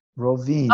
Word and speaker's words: Rovinj: Rovinj. How do you spell it? – R-O-V-I-N-J. – Rovinj. Okay. – Yes, exactly.